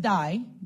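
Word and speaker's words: die (δεῖ): But the word for us in the Greek here that Jesus uses is the word, is the Greek word DEI, and it's pronounced die, D-I-E, just like that.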